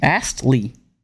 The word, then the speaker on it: Astley: Astley. – Astley? – Astley.